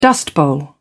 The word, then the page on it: dust bowl: British English